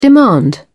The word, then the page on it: demand: British English